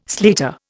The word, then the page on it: slater: Indian